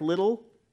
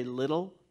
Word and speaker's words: Liddell: Eric Liddell, you know, his name is pronounced or spelled L-I-D-D-E-L-L. So I want to say Liddell, but I have to train myself to say Little, because apparently people like who know more about stuff than I do say it's Little.